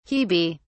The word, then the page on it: hebe: British English